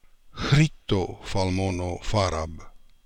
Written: Khritofalmonofarab

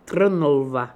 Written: Trnlva

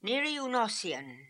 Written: Nirioonossian

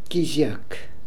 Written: Keeziak

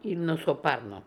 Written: Ilnosoparno

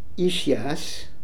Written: ishias

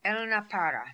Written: Elnapara

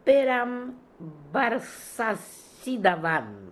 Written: perambarsasidavan

